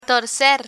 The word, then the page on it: torcer: spanish